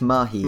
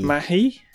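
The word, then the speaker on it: Mahé: how do you say that? – It's Mahi. – Mahi? – Yeah.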